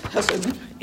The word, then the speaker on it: chesed: And it's spelled chesed, but it's hesed.